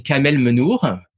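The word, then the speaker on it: Kamel Mennour: So it's I'll let you pronounce it because your French accent is much better than mine. – Yeah. The gallery Kamel Menour.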